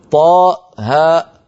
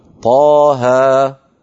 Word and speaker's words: طه: لا نقول حاء ميم بل نقول حاميم، لا نقول طاء هاء بل نقول طاها.